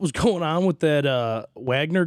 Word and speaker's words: Wagner: And this Wagner group, that from when he pronounced it, the dude that runs this group says Wagner. So I'm like, okay, it's Wagner.